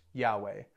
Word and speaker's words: Yahweh: But most scholars who study Hebrew would say that it was most likely pronounced Yahweh.